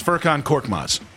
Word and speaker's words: Furkan Korkmaz: It's Furkan Korkmaz.